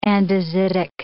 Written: andesitic

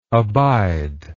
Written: abider

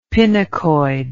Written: pinacoid

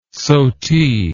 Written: sotie

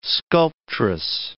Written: sculptress

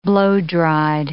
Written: blow-dried

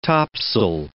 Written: tops'l

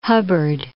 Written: Hubbard

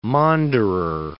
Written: maunderer

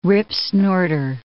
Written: ripsnorter

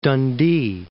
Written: Dundee

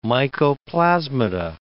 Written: mycoplasmata

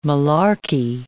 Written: malarky

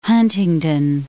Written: Huntingdon

